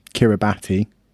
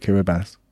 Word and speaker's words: Kiribati: I've got Kiribati, which is mostly pronounced Kiribati. But apparently it's called Kiribati.